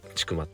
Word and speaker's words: 千曲: たいと思いますがあの、まあ、僕はあのそもそも長野県の千曲市というところに拠点を置いて、えー、活動をしています。あの千曲市ってねあの千あの関数字の線が曲がるって書いて千曲って読むんです